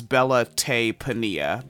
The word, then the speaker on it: Bella Te Pania: So again, I think the pronunciation is Bella Tay Pania. B-E-L-L-A space T-E space P-A-N-I-A. Now, right.